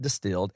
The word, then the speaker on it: DSTLD: It's D-S-T-L-D. You pronounce it Distilled.